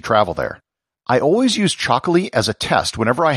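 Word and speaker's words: Txakoli: It's called Chocolí, spelt with a TX instead of a CH, and it's produced in the Basque country.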